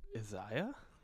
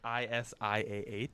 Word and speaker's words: Isiah: Well, they need to figure something out because it's not Isaiah. I'm taking that. It's Isiah.